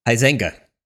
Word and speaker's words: Huizenga: Wayne Huizenga, how do you say his name? – Huizenga. – Huizenga.